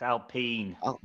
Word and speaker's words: Alpine: It's Alpine. – That sounds horrible. That sounds horrible. I'm just going to pretend it's like some cereal and it's Alpine because I'm ignorant, culturally ignorant.